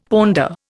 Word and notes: ponder: Indian

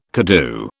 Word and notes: koodoo: British English

